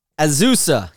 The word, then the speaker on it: Azusa: And we start today in California, just east of Los Angeles, in the community of Azusa, California. That's how I'm pronouncing it. A-Z-U-S-A, California.